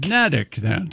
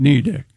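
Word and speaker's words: Neddick: It rhymes with medic, according to this. – Nedick. – Nedick, not Knee Dick. – It sits on the small Nubble Island. – What? – Nubble. N-U-B-B-L-E.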